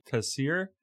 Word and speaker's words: Taseer: But I think our listeners should be reminded that the assassination of the governor, Mr. Taseer, am I pronouncing it right? – Yes.